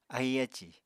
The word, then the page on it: ahi ácì: Apatani